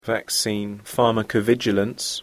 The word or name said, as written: vaccine pharmacovigilance